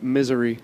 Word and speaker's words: Missouri: I like how they say it, misery.